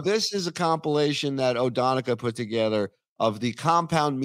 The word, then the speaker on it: Odonnchadha: So apparently it's pronounced Odonica.